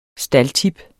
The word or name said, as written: staldtip